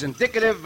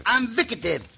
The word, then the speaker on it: indicative: Say a word by syllables, and it is very easy to pronounce. – Uh-huh. – In. – Dick. – Ative. – Ative. – Indicative. – Invicative.